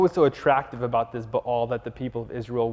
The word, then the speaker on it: Baal: Now, I keep saying Baal, but the correct pronunciation is Baal.